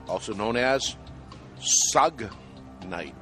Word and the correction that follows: Suge Knight: Suge Knight.